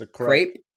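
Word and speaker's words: crêpe: I don't know how to say it properly, but I know what crepe. It is. – It's a crepe. – Crepe. A crepe? Fuck the English language. How the fuck is a crepe when it's spelled C-R-E-P-E? Fuck off. – I'm pretty sure there's supposed to be a little like a comma or something over there. – Yeah, it's French, isn't it? – Yeah, I think so.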